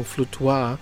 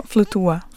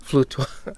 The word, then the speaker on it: Flutuar: That was Johnny Hooker and Lineker with the song Flutuar. Did I say that right? – It's more Flutuar. – Flutuar.